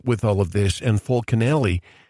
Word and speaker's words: Fulcanelli: Fulcanelli. Am I saying that right? Yep.